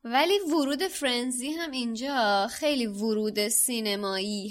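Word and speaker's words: Firenze: یه جوریه که آدم احساس میکنه باید فایر بخونه، ولی در اصل اسمش فرنزیه که شما تو کتاب صوتی هم گوش بدی همین فرنزی خونده میشه.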